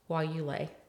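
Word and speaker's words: guayule: somebody was studying while you lay.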